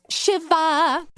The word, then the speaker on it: Shiva: My Jewish mother literally asked me if it was a song about sitting Shiver. I was like, no, but thank you, Mommy. Does pronounce shiver. Clearly, it's Shiva. It's S-H-I-V-A.